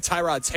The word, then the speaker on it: Tyrod: By the way, that pronunciation has changed again. I've been told emphatically by Packers PR that it's Tyrod Taylor.